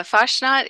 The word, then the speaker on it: Fasnacht: Is it Fasnacht? Fasnacht? – Yeah, Fastnacht is a festival in Helvetia in Randolph County.